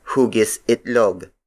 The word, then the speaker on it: hugisitlog: Another Tagalog word for oval is hugisitlog. Hugisitlog. Hugisitlog. Hugisitlog is spelled as H-U-G-I-S hyphen I-T-L-O-G.